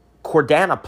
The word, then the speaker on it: Chordana: and that's spelled C-H-O-R-D-A-N-A. I believe it's only one N. If not, try two, but I think it's one, but it's like the word chord, like a musical chord.